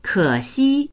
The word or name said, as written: ke3xi1